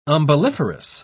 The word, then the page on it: umbelliferous: British English